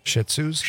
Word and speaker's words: Shih Tzu: What do you pronounce those? Shih Tzus? – Shih Tzus. – Shih Tzus. Yeah.